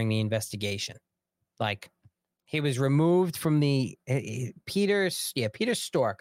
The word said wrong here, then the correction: Strzok: I think the Z is silent or silent. Peter Strzok. I'm sorry. Peter Strzok is his name. That's how you say his name.